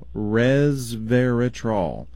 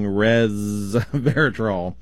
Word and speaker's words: resveratrol: R-E-S-V as in Victor, E-R-A-T-R-O-L, resveratrol. – Okay. – And the problem is the S is pronounced more like a Z, so you can get where I'm saying resveratrol.